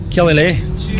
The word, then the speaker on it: Killyleagh: Killyleagh.